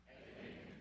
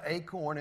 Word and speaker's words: Achan: Achan. You should remember, it's not acorn, it's Achan.